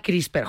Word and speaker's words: CRISPR: Y, entonces se suele pronunciar como si hubiera una E entre la P y la R, CRISPR. – Ah, CRISPR, jolín, pues anda que yo, poco lista ha estado.